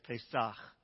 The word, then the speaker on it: Pesach: This word, Passover, the Hebrew word, if I pronounce this correctly, is Pesach.